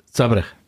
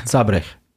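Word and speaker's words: Zábřeh: Zabrech. Ja. Spricht man das H? Okay. – Ja. – Das ist jetzt echt Bildungspodcast heute, sehr gut. Zabrech.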